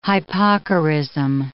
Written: hypocorism

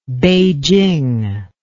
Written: Peiching

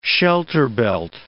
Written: shelterbelt

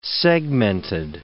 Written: segmented

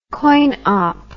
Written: coinop

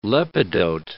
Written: lepidote

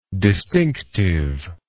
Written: distinctiveness

